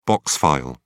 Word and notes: box file: British English

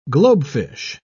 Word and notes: globefish: British English

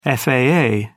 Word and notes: faa: British English

